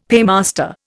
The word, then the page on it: paymaster: Indian